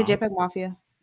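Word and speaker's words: JPEGMafia: Yeah, JPEG Mafia.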